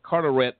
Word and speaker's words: Carteret: Amanda Hogan was among dozens of suspects arrested two years ago as part of a long-running drug sting run by the Cartel County Sheriff's Office. I think I pronounced that right, Carteret County Sheriff's Office.